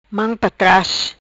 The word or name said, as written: Mam pa tras